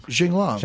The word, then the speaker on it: Li Jinglong: I was asking how to pronounce his name because he deserves. – Li Jinglong. – Li Jinglong. – Jinglong. – Jinglong. – Yeah.